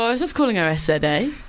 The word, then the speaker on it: SZA: I think we should play a song called Normal Girl, and it is by SZA, which is just SZA. Which for a long time I was saying it wrong.